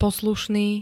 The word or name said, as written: poslušný